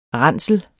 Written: ransel

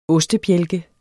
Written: ostebjælke

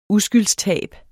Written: uskyldstab

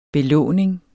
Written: belåning

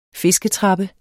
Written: fisketrappe

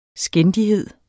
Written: skændighed

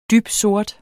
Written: dybsort

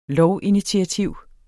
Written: lovinitiativ

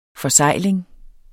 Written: forsegling

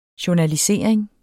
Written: journalisering

